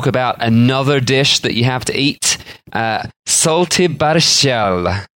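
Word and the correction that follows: Šaltibarščiai: which I've definitely pronounced wrong. – Yeah, it sounds like you absolutely decimated that. – Let me try this again. Shultai burshai. – That sounds better.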